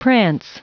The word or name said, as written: prance